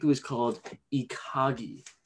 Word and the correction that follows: ikigai: right? – Ikigai. – Ikigai. Yeah, there is no way I was getting that.